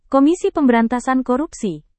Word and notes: komisi pemberantasan korupsi: Indonesian

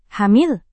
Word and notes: hamil: Indonesian

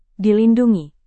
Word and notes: dilindungi: Indonesian

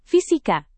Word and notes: fisika: indonesian

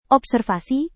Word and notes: observasi: Indonesian

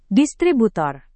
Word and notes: distributor: Indonesian

